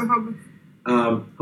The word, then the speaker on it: Rehoboth: Yeah, Rehoboth.